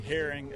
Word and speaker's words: Herring: Clint, C-L-I-N-T, Herring, H-E-R-R-I-N-G.